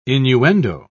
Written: innuendo

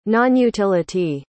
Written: nonutility

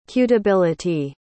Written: cutability

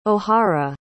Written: ohara

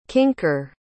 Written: kinker

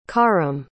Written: kharim

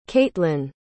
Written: caitlan

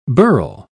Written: birl

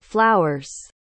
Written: flowers

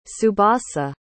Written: tsubasa

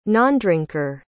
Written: nondrinker

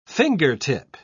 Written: fingertip

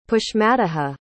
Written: pushmataha

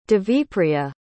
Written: devipriya

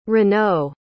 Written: renaud

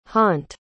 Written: hont

